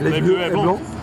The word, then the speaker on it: Les Bleus et Blancs: How was my pronunciation that time? – Les Bleus et Blancs. That's very good. – Yeah, I dropped the C.